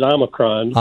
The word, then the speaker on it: Omicron: Okay, so is it Omicron? Omicron? How do you pronounce the variant? – Yeah, I pronounce it Omicron.